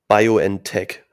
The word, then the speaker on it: BioNTech: Meine Erstimpfung mit BioNTech habe ich gelernt, spricht man das aus.